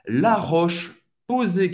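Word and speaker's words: La Roche-Posay: Euh, roche, c'est agaté un son hein? La Roche, non, y prononcer o E. Posé. A Y tem o son E aberto. La Roche, posé.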